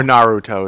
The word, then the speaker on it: Naruto: And he's seeing, like, Dragon Ball Z and, like, Naruto. Naruto. – Or Naruto is technically the pronunciation. Yeah. But I say Naruto because I'm American.